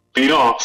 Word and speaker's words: Pinot: Uf, Pinot con acento en la O, ¿verdad? – Pinot, sí.